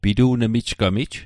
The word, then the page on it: pitu'nmijgamij: Mi'gmaq